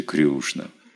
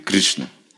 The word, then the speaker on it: Кришна: Но санскрит Кришна. Кришна. Если чистый санскрит, так я слышал объяснить. Кришна. Кришна. Там нет долгое «и», допустим. Или Кришна, нет этого долгого.